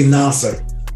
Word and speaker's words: Nasar: It's actually Nasar.